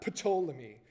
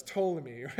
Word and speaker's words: Ptolemy: Because I'm guessing the P is silent. It's not Ptolemy. So it's Ptolemy, right? I think so.